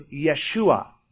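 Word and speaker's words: Yeshua (ישוע): The Hebrews do not pronounce J. They don't say Jerusalem, it's Yerushalayim. And so Joshua was Yeshua.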